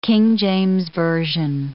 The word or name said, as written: King James Version